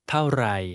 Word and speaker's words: เท่าไร: ไม่ค่อยดีเท่าไหร่ครับเท่าไหร่ไรไรเท่าเท่าไรไม่ค่อยดีเท่าไรครับ This word for very is pronounced with a falling tone followed by a low level tone.